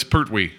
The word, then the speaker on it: Pertwee: Jason Isaacs and Sean Petrie or it's Pertwee. Pertwee? Yeah.